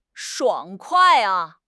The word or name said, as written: 爽快